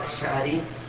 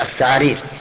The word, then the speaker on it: الشعارير: أي كأنه نطق بها هكذا الثعارير يقول الشعارير